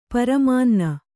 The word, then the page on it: ಪ್ರಮಾಣನ: pramāṇana